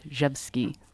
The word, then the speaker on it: Rzewski: It's spelled R-Z-E-W-S-K-I, but pronounced Dzhevsky.